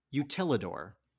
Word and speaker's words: Utilidor: Utilidor. – Utilidor. May I have a definition, please? – A series of underground tunnels that help Disney workers go from land to land without being seen. – Utilidor.